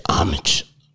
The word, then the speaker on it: Homage: I had to stare at the mirror all day, point to myself, and look and say, Homage, Homage, Homage.